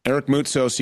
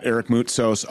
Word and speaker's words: Eric Moutsos: Eric Moutsos, you can find him at Eric Moutsos on Instagram, E-R-I-C-M-O-U-T-S-O-S.